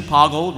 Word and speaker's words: Poggle: Okay, Poggle the Lesser, so official pronunciation, Poggle rhymes with boggle.